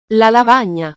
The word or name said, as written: la lavagna